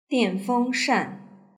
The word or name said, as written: dianfengshan